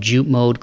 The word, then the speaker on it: Jupmode: And that's none other than John Amato, owner of Jute Mode Clothing Company based in my hometown of Toledo, Ohio. Jute Mode is spelled J-U-P-M-O-D-E.